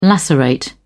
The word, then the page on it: lacerate: British English